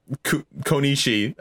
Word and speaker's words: Konishi: I don't think I was stuck on Konishi as long as I was stuck on that fight. – You actually said it right.